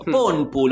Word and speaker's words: Ponpu: Ponpu, ¿lo ubican el Ponpu? No. Así como se pronuncia. – No lo tengo. – P-O-N-P-U. Es un juego argentino.